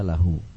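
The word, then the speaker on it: lahu (لهُ): Nah, jadi dibaca laha, ini salah. Muhammadun, kalau tulisannya seperti itu ya, antum bisa lihat nomor 9 tulisan dhamir ha ya, dibacanya lahu, dibacanya lahu. Muhammadun lahu. Kalau laha itu untuk perempuan, itu untuk perempuan.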